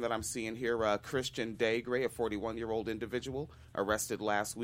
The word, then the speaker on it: Daigre: It's pronounced Daigre. – Daigre.